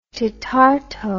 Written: tetarto

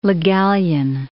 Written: Le Gallienne

